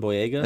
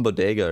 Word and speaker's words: Boyega: John Boyega? – Is it Boyega? – What is it? What I thought it was like John Bodega or something. – Bodega? There's no D in it.